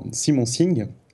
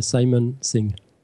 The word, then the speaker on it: Simon Singh: Et en fait, cette découverte, je l'ai fait avec un auteur, Simon Singh. Je l'ai bien prononcé, c'est bon ? – Non, c'est Simon Singh.